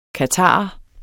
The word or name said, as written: qatarer